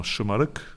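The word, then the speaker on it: Şımarık: Ja, genau, das S spricht man nicht S aus, sondern mit dem Sch. – Habe ich okay, dann habe ich das habe ich richtig, richtig ausgesprochen, genau, Schimmerig.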